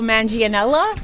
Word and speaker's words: Manganiello: I'm going to get his last name wrong. – I know. – Joe Manganiello? – Oh, you nailed it, actually.